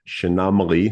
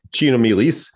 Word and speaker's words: Chaenomeles: Chenomaly, it's one of those words that doesn't sound like it's spelled at all. Chaenomeles. Chaenomeles is pronounced Chenomaly, not Chenomeles, like you might think.